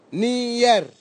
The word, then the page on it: nier: Nafe; Tanna